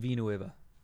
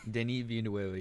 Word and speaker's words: Denis Villeneuve: It is a science fiction film directed by, I'm going to butcher this man's name, Denis Villeneuve? I think that's how you pronounce it. – Denis Villeneuve. – What? – Denis Villeneuve, yeah.